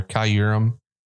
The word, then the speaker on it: Kyurem: Pronounce the Y a little bit more. Kyurem.